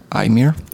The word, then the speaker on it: Eimear: It's an Irish name, I believe. E-I-M-E-A-R. Aymer. That's how I would say it.